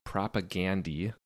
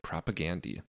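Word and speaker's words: Propagandhi: Your homework, everyone, is to listen to Victory Lap by Propagandy. And I think they pronounce it Propagandy. I always say it Propagandy because I assume that the dude's name was not Gandhi.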